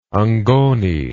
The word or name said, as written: Ngoni